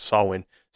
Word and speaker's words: Samhain: But it is pronounced Samhain. Sometimes you can also see it spelt more phonetically the way it's pronounced as S-A-U-I-N.